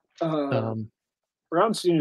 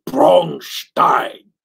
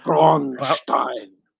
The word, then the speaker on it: Braunstein: Brownstein is perfectly acceptable American pronunciation, because I think the German pronunciation is Braunstein. Braunstein.